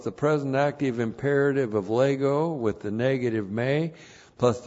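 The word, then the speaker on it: lego (λέγω): L-E-G, long O, lego.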